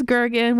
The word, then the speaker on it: Gergen: I was telling these wonderful people earlier that my last name is Gergen, which, yes, rhymes with Bergen.